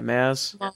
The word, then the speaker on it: Maz: But so probably one of my new favorite characters is Maz. Did I say that right? Yeah, Maz.